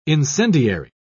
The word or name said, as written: incendiary